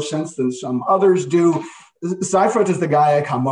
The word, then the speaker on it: Seifert: I don't know how to say his name in English. Alwin Seifert, I guess. – Seifert, yeah. Let's get Seifert in German.